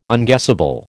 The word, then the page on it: unguessable: American English